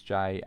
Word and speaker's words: Jay: My name is Jay, and you can contact me on Twitter as J29ers.